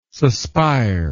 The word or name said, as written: suspire